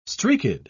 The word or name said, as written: streaked